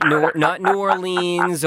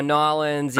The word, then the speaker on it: New Orleans: Now, will people single me out because I'm actually pronouncing New Orleans the way it's spelled? – God bless you for it. – Because there are some cities, I realized this when I was in Australia, it's like the way you show you're from there is by completely mispronouncing it. – New Orleans, not New Orleans or Nolens, you know, stuff like that.